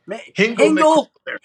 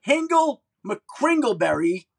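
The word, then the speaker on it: Hingle McCringleberry: I also want to ask you, is it true that you once paid Von Miller's $11,000 fine for mimicking – you're Hingle McKingleberry? Am I saying that right? – McKringleberry. – Hingle McKringleberry, yep.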